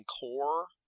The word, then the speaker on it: Corps: Okay, are you mispronouncing core, or is it corpse, like a dead person? – It might be core. C-O-R-P-S? – No, it's okay. Core. You were saying it right.